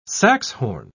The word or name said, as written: saxhorn